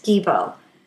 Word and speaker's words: Gebo: The first rune we're going to look at from Freya's Æt is Gibo. It's spelled G-E-B-O.